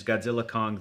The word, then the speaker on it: Godzilla x Kong: I guess it hasn't come out yet, but I asked the director, and the director, Adam Wingard, said the official out loud pronunciation is Godzilla Kong, the New Empire.